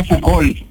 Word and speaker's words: Foucault: Jorge Foucault, F-O-U-C-A-U-L-T. – C-A-L-T. – No, F-O-U-C-A-U-L-T. – U-L-T, mira, ¿de dónde?